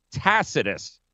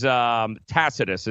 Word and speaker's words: Tacitus: B-O-D-E. Bodie Express, High Call, and it's Tacitus. The C is actually pronounced like an S, not a C. It's not Tacitus. It's actually Tacitus with an S.